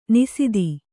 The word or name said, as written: ನಿಸೀಧಿ